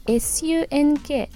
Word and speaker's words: sunk: sunk ですなので一番最後の sunk の音が「あ」の音に一番近いです